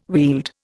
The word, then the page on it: wield: Indian